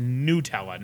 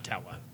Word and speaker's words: Nutella: Hazelnut spread, which is correctly pronounced Nutella, not Nutella. People get that wrong because they think it's nut like hazelnut, but it's not.